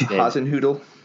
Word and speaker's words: Hasenhüttl: I, I forget how to say his Ralph his Hasenhüttl. Did I get it right? – That was nice. good. Yeah, you got it.